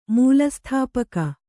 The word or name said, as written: ಮೂಲಸ್ಥಾಪಕ